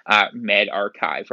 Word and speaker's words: MedRxiv: So MedRxiv, which I want to thank the medical researcher who told me, because I was reading it MedRxiv, because I'm like, oh, that's prescription. But I could actually see it's probably the Greek letter, it would be pronounced Kai, MedRxiv, right?